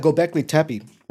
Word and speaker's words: Göbekli Tepe: Göbekli Tepe.